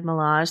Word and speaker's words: Milaje: They pronounce the E at the end of Milaje. So like Milaje, like Dora Milaje.